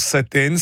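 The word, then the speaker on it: Sa7ten: Oui, à commencer par le nom de l'enseigne qui se prononce Saten, signifiant bon appétit en langue arabe, mais qui s'écrit en réalité Sa, Seven, Ten.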